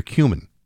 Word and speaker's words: cumin: It has often flavored with botanicals and caraway and dill or fennel, coriander, cloves, or cumin. – Cumin? – Is that how you say that? – That's how you say it. Yep. That's how it is said.